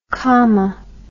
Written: kaama

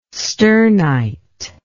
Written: sternite

